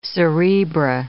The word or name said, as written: cerebra